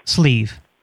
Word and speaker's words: Sleve: Sleeve. – I'm sorry, could you spell it, please? – S-L-E-V-E. – F-L-E-E-Z? – Yeah.